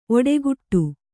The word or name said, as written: ಒಡೆಗುಟ್ಟು